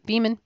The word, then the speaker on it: Beaman: I think it's Bayman, B-E-A-M-A-N.